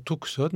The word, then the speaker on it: Tucson: Ça s'écrit T-U-C-S-O-N, je disais toujours Tucson, maintenant ça se dit Tucson.